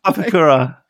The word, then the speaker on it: Papakura: Papakura. Yeah, you got it. That's the one.